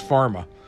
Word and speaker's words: PhRMA: An email from Jared McCloud, a lobbyist with the Pharmaceutical Research and Manufacturers of America, PRMA, which is it's spelled out PHRMA, so it's pharma.